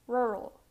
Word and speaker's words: rural: The next mispronounced word is rural. Rural. You need to make the R sound. Rural. Rural.